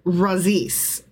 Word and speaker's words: Razis: R-A-Z-I-S. How would you say it? – Razis. – Razis.